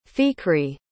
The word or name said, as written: fikri